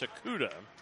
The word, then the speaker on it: Tsukada: Sakuda's name spelled T-S-U-K-A-D-A, but pronounced Sakuda.